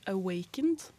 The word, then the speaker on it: Awakened: Først skal vi høre en låt 'Awakened'. Mm, Stemmer godt, det. Det var riktig uttale.